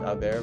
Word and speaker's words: haber: So when we conjugate haber, remember that the H is silent.